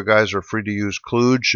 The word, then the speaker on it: kludge: Judge Steve Silverman has ruled that the correct pronunciation, at least for our purposes, is kludge. So kludge is in fudge, folks.